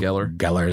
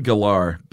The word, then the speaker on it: Gellar: Sarah Michelle Gellar is how I've always heard it. But I'm sure I can say however you want. – I've heard Galar, but I just pronounced it both ways.